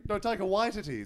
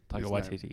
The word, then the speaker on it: Taika Waititi: Taika Waititi is actually how you pronounce Taika Waititi. – Because I feel like everyone in the world was saying Taika Waititi, and then he did a YouTube video where he said his name, I think it was for Vanity Fair, and then the whole world went, oh, shit. – Mm. – That's how you say it.